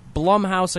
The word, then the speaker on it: Blumhouse: And then I looked it up, and on Twitter, the official Twitter Blumhouse account, somebody tweeted at them, was just like, how do you pronounce this? Is it Blum or Blum? And they responded with Blum like plum. Boom. It's Blumhouse.